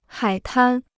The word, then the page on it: 海滩: hǎi tān